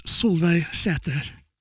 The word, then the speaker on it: Solveig Sæther: This is my guest today, Miss Solveig Sæther. – Hi. Thank you for that Norwegian pronunciation.